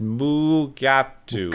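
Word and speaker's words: Mugato: That's the white-horned, fur-like gorilla creature pronounced Mugatu.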